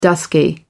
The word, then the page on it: dusky: American English